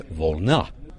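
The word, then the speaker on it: Volna (волна): It's Volna, second syllable stress, Volna.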